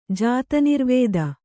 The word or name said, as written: ಜಾತನಿರ್ವೇದ